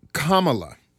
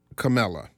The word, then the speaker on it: Kamala: It's Kamala, not Kamala.